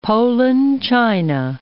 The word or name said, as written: Poland China